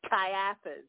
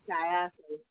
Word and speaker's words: Caiaphas: Caiaphas. There's no L in it. – Okay. That's the only way I know how to pronounce his name. He was... – You got it right, Caiaphas.